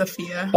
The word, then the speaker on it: Afiya: My name is Afia. – Afia. Spell it for me. – A-F-I-Y-A. – And last name? – Ward, W-A-R-D.